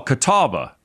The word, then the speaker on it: Catawba: Catawba. – So, you go. – You did well. Catawba.